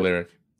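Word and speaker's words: Lyriq: I mean, they did release this one teaser shot of the Cadillac Lyric. Is that how they pronounce it? – Yeah, the Lyric.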